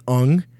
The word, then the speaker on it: Ung: His name's Jonathan Ung. And that's how you pronounce it. – Yeah, it's just NG.